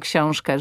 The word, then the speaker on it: książkę: ale że powie książkę, książkę, że to ę zabrzmi nie jako książkę, bo to jest błąd. Tak, tak. To jest hiperpoprawna wymowa. Książkę, rękę, ale że to ę wybrzmi. Rękę.